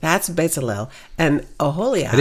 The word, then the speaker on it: Bezalel: Well, his name's pronounced Bezalel.